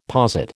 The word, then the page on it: posit: American English